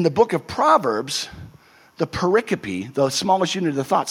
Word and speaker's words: pericope: It looks like pericope, P-E-R-I-C-O-P-E. But it's pronounced pericope.